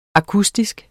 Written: akustisk

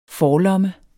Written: forlomme

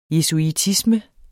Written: jesuitisme

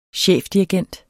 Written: chefdirigent

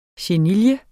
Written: chenille

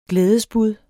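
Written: glædesbud

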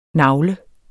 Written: nagle

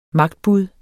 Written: magtbud

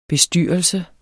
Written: BESTYRELSEN

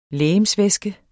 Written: legemsvæske

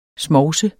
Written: smovs

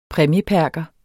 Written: præmieperker

